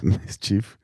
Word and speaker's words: mischief: Mischift, oder wie heißt das? – Mischief. – Mischief. Brt, Mischief. Ist schwierig.